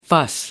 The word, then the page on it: fuss: American English